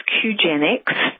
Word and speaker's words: Qgenics: The brand name is Qgenics, and that is spelled Q-G-E-N-I-C-S.